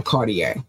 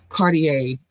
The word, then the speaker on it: Cartier: Oh, Cartier. I better not put an R on it. Cartier, the R is pronounced silently.